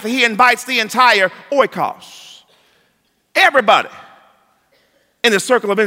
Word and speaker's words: oikos: Now quickly, that word for house in the Greek is pronounced oikos.